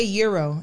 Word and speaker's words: gyro: Like, and I'm not a big gyro person. – I love gyro. – Because they say euro and we say gyro. – Huh? – They said supposedly it's pronounced euro.